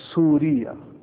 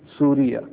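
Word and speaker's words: سوريا: In the Arabic language, Syria is pronounced as Suriya. Suriya.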